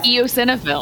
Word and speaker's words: eosinophils: EMS can be fatal, and it's characterized by high levels of white blood cells called, oh God, am I saying this wrong? Eosinophils? – Eosinophils. – Okay, I was close.